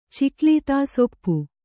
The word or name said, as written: ಚಿಕ್ಲೀತಾಸೊಪ್ಪು